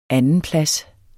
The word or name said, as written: andenplads